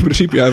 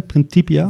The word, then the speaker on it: Principia: Principia? Principia. Die C wat er gewoon in het Latijn als, uitgesproken als een K.